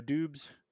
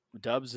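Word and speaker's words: Doubs: Rome also wants you to take a look at Romeo Dubs. I think it's Dubs, isn't it? Even though it's spelled Dubs.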